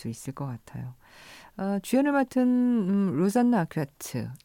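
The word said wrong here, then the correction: Arquette: 이름이 아큐트네요 예, 프랑스 쪽 발음이죠. 그러니까 로자나 아르케트.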